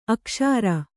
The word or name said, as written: ಅಕ್ಸರ